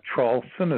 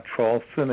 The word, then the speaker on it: Traulsen: My name is Hugh, H-U-G-H. – Mm-hmm. – The last name, Trawlson, is spelled T like Tom, and R-A-U-L-S like Sam, E like Edward, N like Nancy. And the big website, just Google my last name, Trawlson, again, T-R-A-U-L-S-E-N, the second word, and A-N-D, and the third word, Trump, T-R-U-M-P.